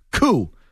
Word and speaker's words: coup: The phrase is Palace Coup, pronounced C-O-O, she writes. Not Palace Q, pronounced C-U-E, as you keep saying.